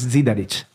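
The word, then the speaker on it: Zidarich: Si, Wahrscheinlich, das hängt davon ab, wenn, wenn ich jetzt das auf Slowenisch, wahrscheinlich werde das anders aussprechen, als wie äh. ich auf Italienisch. – Für mich ist es Sidaric.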